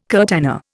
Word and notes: curtana: Indian

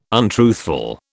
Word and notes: untruthful: British English

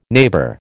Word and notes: neighbour: American English

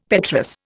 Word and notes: petrous: Indian

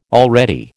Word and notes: already: American English

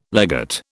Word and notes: legate: British English

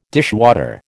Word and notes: dishwater: American English

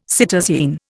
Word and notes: cytosine: Indian

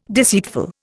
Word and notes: deceitful: Indian

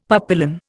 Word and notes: papillon: Indian

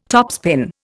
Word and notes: topspin: Indian

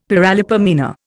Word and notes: paralipomena: Indian

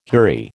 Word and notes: houri: American English

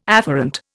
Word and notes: afferent: Indian

